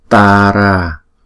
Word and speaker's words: Tara: Còn khi chúng ta đọc tiếng Anh á, người ngoại quốc người ta đọc chữ T này là tờ, Waitara, Waitara. Không có làm như chữ thờ vậy đó.